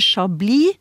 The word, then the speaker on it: chablis: Eh, det heter chablis, selv om det skrives med s til slutt.